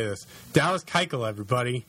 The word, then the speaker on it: Keuchel: It's taken me 15 episodes to learn that Dallas Keuchel's name is pronounced Dallas Keuchel, but I'm going to agree and pick him as the AL Cy Young.